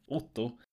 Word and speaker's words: Otto: For example, if I say my name, Otto, usually an American, for example, don't understand which vowels I'm using, so I have to say it either like 16 times or I can just go, Otto, you know.